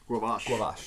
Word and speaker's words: gavage: Called gavage. – Yep. Gavage. – Gavage. That's how I heard someone pronounce it on Chef's Table. – G-A-V-A-G-E. – Guavage.